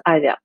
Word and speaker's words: Aavia: Оно пишется как AAVIA, а, то есть две A в начале вот, но произносится Avia.